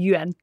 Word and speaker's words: yuan: yuan. Det er rigtigt udtaler det, ikke? – Yuan. – Yuan.